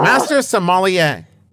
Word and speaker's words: Master Sommelier: Available on Master Sommelier. – You're leaving out a syllable. Oh. Master Sommelier.